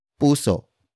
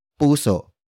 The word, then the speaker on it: puso: It is the focus marker that introduces the main topic of the sentence, which is puso. Puso. Puso. Puso is spelled as P-U-S-O.